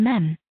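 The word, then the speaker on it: meme: It should be pronounced to rhyme with cream. Mem.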